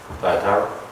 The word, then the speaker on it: Thyatira: Thyatira.